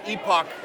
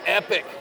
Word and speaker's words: Epoch: And uh, my guest is Joshua Phillips, and he works for, as the Queen's English would say, Epoch Times. That's right. And in America, we call it Epic Times.